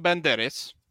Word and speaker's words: Banderas: Antonio Banderas.